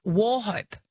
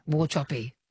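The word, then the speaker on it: Wauchope: On the text line, a place name that's tricky for most, or well, for me anyway, until I found out the pronunciation, is Warhope. – Warhope. Oh, yeah. – Warchoppy. – Yeah, Warchoppy. Warchoppy.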